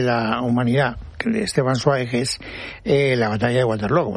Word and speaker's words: Zweig: Sí, pero desde que el bardo nos ha llevado por el camino bien, ahora hay que decir Zweig. – Zweig. – Zweig. Esteban Zweig. – Es así como se pronuncia en alemán.